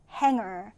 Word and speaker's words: hanger: 여러분 hanger라고 발음하면 안 돼요. 그건 콩글리시고 우리 가수를 얘기할 때도 singer라고 안 하잖아요. singer라고 발음하는 것처럼 우리 같은 원리로 옷걸이라고 할 때 hanger 이렇게 발음해 줍니다.